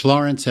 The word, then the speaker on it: Laurance: and me on both of those at Mitch Lawrence, L-A-U-R-A-N-C-E, just because I decide to spell it that way.